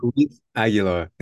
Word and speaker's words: Luis Aguilar: So this is Louis Aguilar. Did I say it right? – Luis Aguilar.